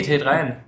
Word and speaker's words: t3n: Live ist live, live kriegen ähm, Der uns entdeckt hat über die, damals, als wir auf Ten waren. Nee, T3N. T3N. Offizielle Aussprache.